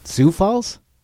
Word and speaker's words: Sioux Falls: Sioux Falls? – Sioux. I said that wrong. This is why I teach music. – S-I-O-U-X? – Yes. – Oh, yeah. Sioux Falls.